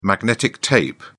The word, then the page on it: magnetic tape: British English